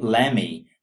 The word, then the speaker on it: lemme: People don't say let me all the time, they say lemme. The colloquial spelling is also interesting. Notice that we spell L-E-M-M-E.